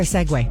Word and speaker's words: segue: I'm surprised by Segway. It's spelled S-E-G-U-E because I would have pronounced that seg.